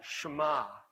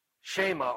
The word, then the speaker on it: Shema: And so Jesus quoted something called the Shema. It's spelled S-H-E-M-A in Hebrew, and everybody knew that word.